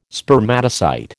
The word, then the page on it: Spermatocyte: American English